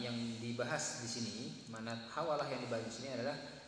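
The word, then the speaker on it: hawalah (حوالة): Dhubita fil matni bi fathin. Naam. Dengan fathah al-muhmalah. Juga sebagian membacanya dengan kasrah ha-muhmalah. Jadi dibaca hawalah, ada yang membaca hiwalah.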